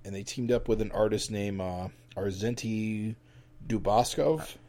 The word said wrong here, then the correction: Dubakov: You're doing it. Dubakov. – You butchered that name, but it's Dubakov. Yeah.